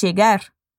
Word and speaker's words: llegar: ¿Notaste también la pronunciación de la doble L? Camila dice llegar.